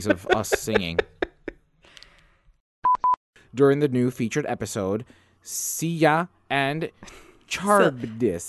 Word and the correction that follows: Scylla: Scylla and Charybdis.